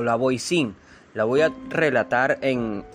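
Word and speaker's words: Voisin: Catherine Montboisin o Montboisin, nacida en Des Halles, 1640, 22 de febrero hasta 1680, conocida como La Boisín o La Boisán, que es la pronunciación de este apellido, fue una supuesta bruja, adivina y envenenadora profesional francesa.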